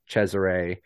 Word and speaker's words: Cesare: This isn't a spoiler because it's just like a weird scene from the book, but like when Cesare, which some people think it's Cesare, but that's fine, it's spelled weird, it's an Italian name,